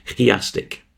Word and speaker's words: chiastic: Most Psalms are chiastic, if you're very Greek in your pronunciation, chiastic, C-H-I, chiasmus, meaning they have a cross structure.